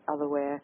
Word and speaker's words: Otherware: and it's spelled OTHERWARE, Otherwear. It's sort of like software, hardware, Otherwear. Otherwear.